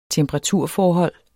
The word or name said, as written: temperaturforhold